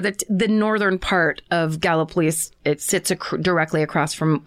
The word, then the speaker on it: Gallipolis: So this story took place in and around the cities of Point Pleasant, West Virginia, and Gallipolis, Ohio, which I can't believe that's the way it's pronounced when it looks exactly like Gallipoli.